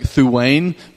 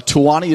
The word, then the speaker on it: Tuwani: Uh, it looks like Thuane, but it's uh, Tuwani is the way that they pronounce it.